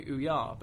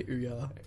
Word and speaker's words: Ouya: I really love how you say Ouya. – Was that not what it's called? – I've always just called it the Ouya. – Oh. – Like just way less syllables.